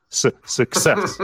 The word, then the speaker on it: success: So you have to say success, right? Success. So the stress is on success.